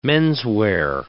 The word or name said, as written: menswear